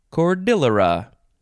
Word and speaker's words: cordillera: Cordillera or cordillera or cordillera. Cordillera. I think the best pronunciation is probably cordillera. It is a noun from 1704.